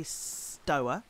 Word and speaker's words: Stower: Well, I have been reading, Frank, a fantastic new book by Adam Stower, who I sometimes call Adam Stower, but I've been told you say Stower to rhyme with feather boa.